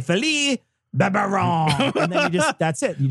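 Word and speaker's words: Barbarin: Philippe Barbarian. Sorry, that's not how it's pronounced.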